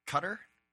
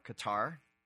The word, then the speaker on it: Qatar: So and then the other thing is Tour of Qatar, or Qatar, pronounced both ways, I've learned, is going on right now.